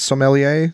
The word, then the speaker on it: sommelier: Is that how you pronounce that, by the way? Is it sommelier? Sommelier? – Sommelier. – Sommelier. Yeah. Okay, perfect.